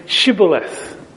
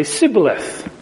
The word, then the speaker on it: Shibboleth: and they would be telling them to say Shibboleth. And if they said, but they wouldn't know how to say it, they would say Sibboleth.